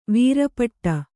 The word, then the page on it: ವೀರಪಟ್ಟ: vīra paṭṭa